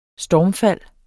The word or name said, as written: stormfald